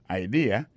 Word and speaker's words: idea: C'est I-D-E-A. Ça veut dire idée. That's a good idea. Alors, accent vient de tomber dans la deuxième syllabe là. One idea. Alors, moi, c'est idéal. Vous faites le fait trois syllabes vraiment. I, D, A. On dit que mot concerne trois syllabes là-dedans, mes amis.